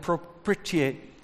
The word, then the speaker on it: propitiate: I better say it right. How do you say that? Propitiate. Thank you. You're much better at it.